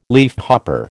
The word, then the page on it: leafhopper: American English